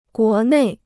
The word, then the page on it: 国内: guó nèi